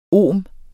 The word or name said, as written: ohm